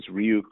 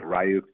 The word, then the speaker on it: Ryuk: And they use TrickBot to deploy ransomware known as Ryuk or Ryuk, depending on how you like to pronounce it.